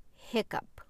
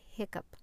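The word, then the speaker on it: hiccough: This is a word that is an alternate spelling, and as far as I know, the only case for the pronunciation of O-U-G-H, hiccup. The word is hiccup. It is more commonly spelled as H-I-C-C-U-P, hiccup, but it can also be spelled with O-U-G-H. And then it takes the schwa sound, and the P sound, p, hiccup, hiccup.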